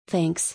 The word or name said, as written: Thanks